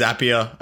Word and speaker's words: Zapier: Yeah, but no, it's Zapier, I think, is like the CEO. That's what he says is the right way to say it. So, because they're zaps. You create a zap, so it's a Zapier.